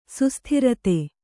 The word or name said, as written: ಸುಸ್ಥಿರತೆ